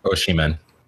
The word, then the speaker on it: Osimhen: Is that how you pronounce it? Osimhen? – Osimhen. – Osimhen. Okay, sorry. Osimhen to Napoli for around 50 million.